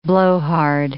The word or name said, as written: blowhard